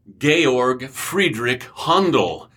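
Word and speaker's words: Georg Friedrich Händel: Well, the short story of What is Messiah starts with Georg Friedrich Handel, if you want to pronounce it correctly in the German.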